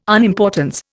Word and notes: unimportance: Indian

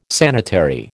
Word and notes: sanitary: American English

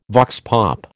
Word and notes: vox pop: American English

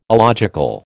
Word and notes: alogical: American English

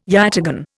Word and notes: yataghan: Indian

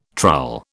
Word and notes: trull: British English

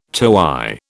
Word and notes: toey: British English

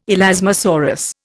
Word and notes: elasmosaurus: Indian

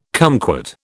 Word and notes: kumquat: British English

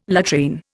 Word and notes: latrine: Indian

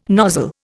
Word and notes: nozzle: Indian

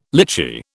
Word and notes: litchi: British English

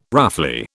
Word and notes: roughly: British English

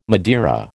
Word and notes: Madeira: American English

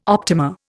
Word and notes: optima: Indian

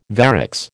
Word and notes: varix: American English

